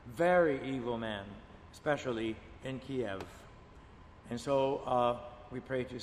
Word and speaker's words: Kiev: It is not Kiev, it's Kiev.